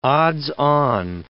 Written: odds-on

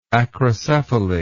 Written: acrocephaly

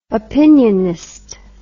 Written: opinionist

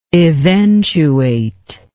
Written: eventuation

